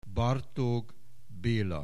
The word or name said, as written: Bartók, Béla